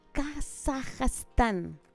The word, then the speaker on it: Kazajstán: Vamos a partir conociendo a un señor nacido en Kazajstán.